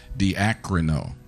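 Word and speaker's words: diakrino (διακρίνω): Discerning comes from a Greek word that's pronounced diakrino. Mm-hmm.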